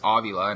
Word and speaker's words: Avila: and he finally said, that's how you pronounce my last name, is Avila, and everyone's been calling him Avila.